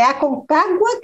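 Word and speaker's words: Aconcágua: É a Concagua que fala, pronuncia? Me corrige aí, É, é isso aí.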